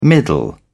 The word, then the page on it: middle: British English